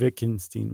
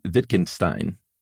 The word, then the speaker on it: Wittgenstein: And if you don't, then you're just shooting yourself in the foot, essentially, was what Ludwig Wittgenstein was saying. – Yeah, I think, unfortunately, Wittgenstein. Well, I didn't see it, but I think the second letter in the EI in German is the one that's favored.